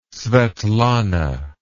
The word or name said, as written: svetlana